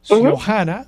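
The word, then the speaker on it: Xiojana: Xiomara, eh, tú eres la eh, dueña junto a tu esposo de Finca Siojana. – Ciojana se escribe como el comienzo de tu nombre, Xiomara, X-I-O-J-A-N, Finca Siojana, que es un proyecto agrícola dedicado al café en el municipio de Las Marías.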